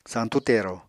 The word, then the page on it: santú tero: apatani